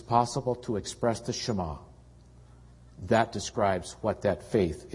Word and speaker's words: Shema: It's spelled S-H and then a Hebrew character, M-A, Shema, Shema.